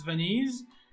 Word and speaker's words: Venise: Oh, Venice. I don't even know how it's pronounced. – I mean, yeah, in, in French it's Venise, in English, it's Venice.